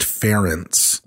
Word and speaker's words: Ferenc: She got pregnant, and her future husband, who was going to marry her, not this guy, but the guy, Count, it's spelled F-E-R-E-N-C, but one of the things I read said that it's pronounced Ferentz.